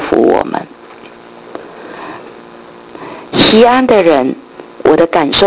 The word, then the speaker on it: 锡安: 锡安希伯来话念成吉翁，它的意思就是凸出来，高出来。